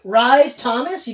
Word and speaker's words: Rhys Thomas: But if you went Rhys Thomas, you'd be like, that's not how you pronounce... Yes. But that is sweet. – In fact, that is the exact way to pronounce it.